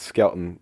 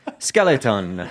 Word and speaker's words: Skelton: No, it's Skelton. Okay. S-K-E-L-T-O-N. – Yeah, yeah. I always say it's Skelton, is that wrong? – I maybe pronounced maybe too many of the letters. I treat it like a Hawaiian word.